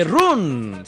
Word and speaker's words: run: Sí, pero no se dice run. Se dice ¿cómo se dice? – No, run, no, run. Incluso casi más con A.